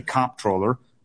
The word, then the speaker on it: comptroller: I happen to say comptroller. I hit it hard on the first syllable.